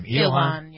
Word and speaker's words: Ilhan: Okay, speaking of stealing oxygen, number nine is Congresswoman Ilhan. Is that how you pronounce it? How do you pronounce her name? – Ilhan.